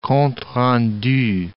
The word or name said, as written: compte rendu